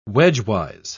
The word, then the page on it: wedgewise: British English